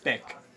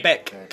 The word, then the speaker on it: beag: Vig, because of grammar, it would be vig, but small in Irish would be beog, which is B-E-A-G. Yeah. And beg in Scots Gaelic is pronounced... – Beck. – Yeah. – Beck. But the G is almost like a K. – Yeah. – So, for example... – It's like the band Beck, like, yeah.